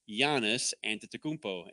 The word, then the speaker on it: Giannis Antetokounmpo: They're saying it's Giannis Antetokounmpo.